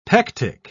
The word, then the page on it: pectic: British English